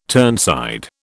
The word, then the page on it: turnside: British English